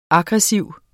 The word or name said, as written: aggressiv